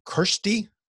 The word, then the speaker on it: Kyrsty: Kyrsty, did I say it right? – Kyrsty.